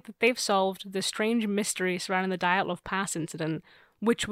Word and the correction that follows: Dyatlov: Actually, it's Dyatlov.